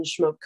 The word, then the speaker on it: Schmoke: And you can find me on Facebook and Instagram under Kristen Schmoke Coaching. The hardest part is spelling my name. It's Kristen with two eyes, and Schmoke is spelled like smoke, only with a CH.